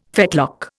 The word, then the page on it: fetlock: Indian